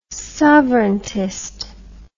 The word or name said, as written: sovereigntist